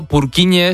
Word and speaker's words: Purkinje: Es que se escribe, en español sería Purkinje. – ¿Purkinje? – Pero Purkinje es en checo, que es por porque es el apellido del descubridor.